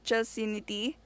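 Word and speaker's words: Chelseanity: Mine My username is at Chelsinity.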